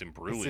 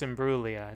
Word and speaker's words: Imbruglia: Also, I looked up Natalie, and according to the Wikipedia page pronunciation, there's no G. – Oh, so it's Imbruglia. – It's just Imbruglia. It's silent. Or there is a G, but it's silent.